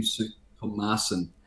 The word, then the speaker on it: Soutphommasane: Well, the former Race Discrimination Commissioner, uh, Tim Soutphommasane.